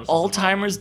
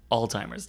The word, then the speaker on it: Alzheimer's: Alzheimer's disease. – You mean Alzheimer's disease? – Yeah, like we don't say it with the Z here.